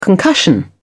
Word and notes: concussion: British English

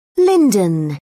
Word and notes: linden: British English

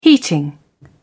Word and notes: heating: British English

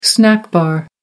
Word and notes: snack bar: American English

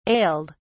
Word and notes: ailed: British English

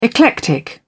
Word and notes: eclectic: British English

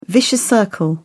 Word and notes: vicious circle: British English

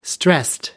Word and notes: stressed: British English

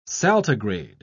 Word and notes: saltigrade: British English

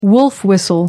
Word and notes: wolf-whistle: American English

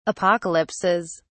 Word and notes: apocalypses: British English

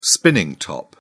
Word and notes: spinning top: British English

